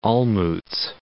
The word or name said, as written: Olmutz